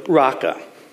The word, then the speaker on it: Raca: Now, I know it looks like paka, but trust me, that's a row, okay? So, raka.